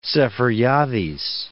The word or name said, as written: Seferiades